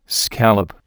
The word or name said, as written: scallop